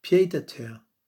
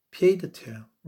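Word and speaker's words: pied-à-terre: And it's the French pronunciation is pied de terre, pied de terre.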